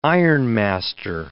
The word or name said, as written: ironmaster